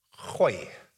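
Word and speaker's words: gooi: You have to kind of start yearing your throat. Khoi.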